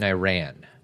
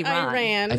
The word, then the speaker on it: Iran: You were born in Iran. – Iran. – Iran. – Iran. – Did I pronounce that wrong? – The first time you did, yes.